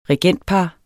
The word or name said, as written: regentpar